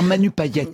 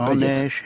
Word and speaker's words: Payet: Mais il faut dire Manu Payet. – Oui, Payet. En français, on prononce tout le temps. Non, monsieur, c'est Payet. À la Réunion, on dit Manu Payet.